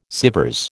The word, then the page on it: Sèvres: American English